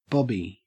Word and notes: bobby: Australian English

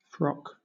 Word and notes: frock: British English

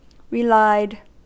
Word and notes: relied: American English

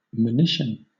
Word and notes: monition: British English

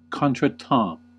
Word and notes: contretemps: American English

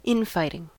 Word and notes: infighting: American English

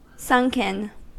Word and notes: sunken: American English

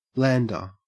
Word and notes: lander: Australian English

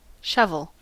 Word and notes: shovel: American English

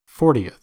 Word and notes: fortieth: American English

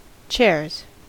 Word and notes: chairs: American English

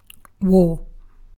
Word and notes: war: British English

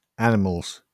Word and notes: animals: British English

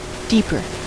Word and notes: deeper: American English

